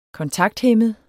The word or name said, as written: kontakthæmmet